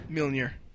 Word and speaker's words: Mjolnir: Mjolnir. – Mjolnir? – Mjolnir. It's not spelled like the way it sounds, but it's it sounds like a Swedish STD.